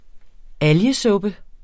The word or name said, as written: algesuppe